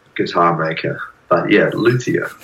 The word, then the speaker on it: luthier: We chatted about how do you say it? Is it a luthier? Is it a luthier? Is it a luthier? – Most people say luthier.